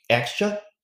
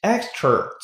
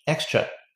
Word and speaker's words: extra: 我们来看细节，the extra effort 就是额外付出的努力，extra e x t r a extra extra 这单词表示额外的、多余的。注意结尾的 a 音呢，不许带儿化音，您不能读成 extra，错了，它就是一个很死、很硬的 extra extra 这样的声音。